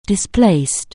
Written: displaced

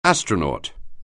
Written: astronaut

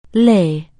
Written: lay

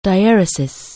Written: dieresis

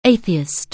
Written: atheist

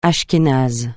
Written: ashkénase

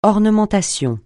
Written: ornementation

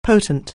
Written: potent

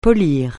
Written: polir

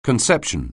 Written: conception

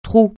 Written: trou

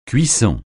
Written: cuisson

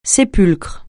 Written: sépulcre

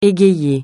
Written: égayer

